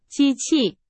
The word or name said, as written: 机器